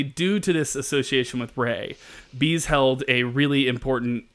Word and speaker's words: Ra: But I learned in looking up this stuff for this fact that Ra is actually pronounced Ray. And that we've all just learned it wrong.